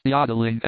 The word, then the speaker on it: jodeling: The jodeling, pronounced yodeling emphasis on the O, continued, and was very pleasant and inspiriting to hear.